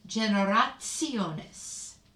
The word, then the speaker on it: generationes: Like the T in generationes is pronounced like a TS or like Mozart. Generationes.